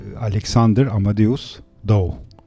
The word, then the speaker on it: Alexander Amadeus Dow: Alexander Amadeus Dow. İnşallah doğru telaffuz ettim ismini. Evet, aynen öyle.